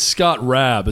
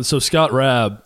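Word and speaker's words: Scott Raab: It's Scott Rabb is how you pronounce it, right? – Right, yeah. – Scott Rabb,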